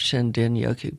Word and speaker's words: Shinrin-yoku: It's actually pronounced Shinden-yoku, but it's spelled Shinrin-yoku.